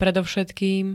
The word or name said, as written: predovšetkým